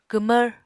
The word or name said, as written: kwmvr